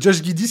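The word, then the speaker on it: Giddey: C'est Josh Giddy si tu le prononces en anglais.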